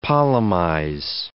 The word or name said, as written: polemize